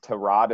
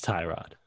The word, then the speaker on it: Tyrod: It's like when the Tyrod Taylor came out and said his name's actually pronounced Tyrod. It's like – yeah, no, he's Tyrod. – It's Tyrod. – Yeah. – It's been decided.